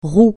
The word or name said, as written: roue